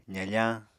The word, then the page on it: zafañ: apatani